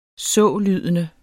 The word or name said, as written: sålydende